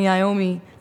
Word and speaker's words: Iommi: Black Sabbath, Tony Iommi.